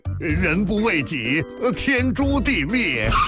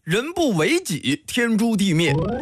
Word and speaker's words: 人不为己，天诛地灭: 人不为己，天诛地灭。 – 等等，这句话的正确读音应该是人不为己，天诛地灭。